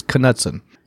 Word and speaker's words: Knudsen: and it's spelled Knudsen, and the K is actually pronounced, so it's Knudsen.